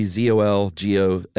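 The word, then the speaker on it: Czolgosz: I would say Zolgos. – That's pretty good. Gozar. – Silent C, Zolgosz. Mm-hmm.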